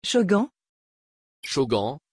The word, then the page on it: chogan: French